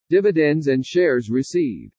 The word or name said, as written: DIVIDENDS AND SHARES RECEIVED